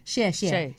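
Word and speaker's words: Xie xie (谢谢): Did I say it right? – Xie xie. – Xie xie. – Xie xie. Very good. Yeah, we've got to be really careful. It sounds like something else there.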